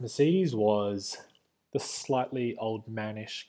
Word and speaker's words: Mercedes: Mercedes, sorry. Yeah, I throw out the E a bit more.